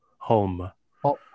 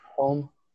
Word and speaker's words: Hulme: Hulme. How would you pronounce that? – Home. – Holm. Okay. – Home.